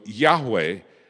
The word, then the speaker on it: Yahweh: The traditional pronunciation of it is Jehovah. But the Hebrew language has no J sound. And its V is more like a W. So Yahweh is probably more accurate.